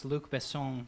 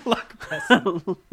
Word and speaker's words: Luc Besson: I'm also the first person in this room to pronounce Luc Besson properly. So I like Luc Besson. He's a good guy. – Luc Besson.